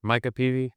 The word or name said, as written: Micah Peavy